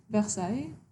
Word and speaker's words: Versailles: Like, do I pronounce it Versailles? – The French way? – What is the French way? – Versailles.